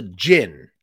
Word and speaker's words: Djinn: It's called The Djinn, and it looks like it's spelled Dudjin, but that's actually, the D is kind of silent, kind of like Django, like Djinn. That's how you do it.